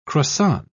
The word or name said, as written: croissant